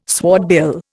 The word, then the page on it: swordbill: Indian